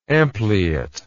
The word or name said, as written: ampliate